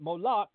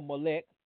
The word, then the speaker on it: Moloch: And from what I understand in my study about it is that those that sacrificed even their firstborn to Molech believed that Moloch or Molech, either way you want to pronounce it, would ensure financial prosperity for their family and future children.